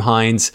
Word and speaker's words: Hines: If you're having any trouble at all, please just reach out to me at the Andrew Hines on Instagram or Facebook. And my last name is spelled H-I-N-E-S.